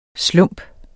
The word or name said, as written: slump